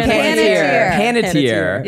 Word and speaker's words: Panettiere: Panettiere.